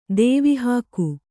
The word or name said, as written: ದೇವಿಹಾಕು